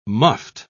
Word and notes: muffed: British English

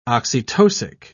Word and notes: oxytocic: British English

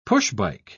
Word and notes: push-bike: British English

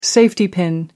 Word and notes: safety pin: American English

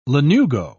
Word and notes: lanugo: British English

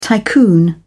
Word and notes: tycoon: British English

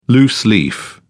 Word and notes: loose leaf: British English

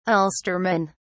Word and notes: Ulsterman: British English

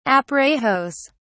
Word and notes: aparejos: British English

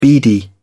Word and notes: beady: British English

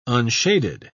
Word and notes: unshaded: British English

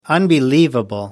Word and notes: unbelievable: American English

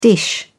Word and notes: dish: British English

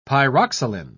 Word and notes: pyroxylin: British English

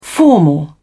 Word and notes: formal: British English

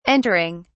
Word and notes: entering: British English

